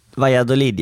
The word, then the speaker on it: Valladolid: Våra snabba vänner på Hyper sponsrar oss, och jag tänker ju direkt på den här mäktiga centerkorten i Valladolid. Uttalar jag rätt, eller? – Valladolid, ja. Helt korrekt.